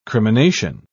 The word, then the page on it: crimination: British English